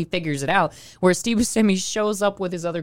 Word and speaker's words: Buscemi: How do you actually say his damn name? – Buscemi. – Okay, yeah. I always say it wrong then.